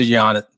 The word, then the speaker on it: Giannis: We can get into Giannis.